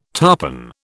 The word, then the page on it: tarpan: British English